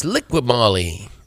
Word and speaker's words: Liqui Moly: Is it Liqui Moly Junior Cup? The Liqui Licky? – That's Liqui Moly. Liqui Moly, Scott.